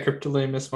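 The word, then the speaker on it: Cryptolaemus: Is it Cryptolamus? – Yeah, Cryptolamus montrosi is how I pronounce it.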